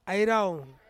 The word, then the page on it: airau: Ansus